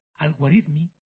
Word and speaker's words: Al-Khwarizmi: Entonces, el Al-Khwarizmi, que es esto pronunciado así, en el siglo XI, fue el autor de varios compendios, tanto de aritmética como de álgebra.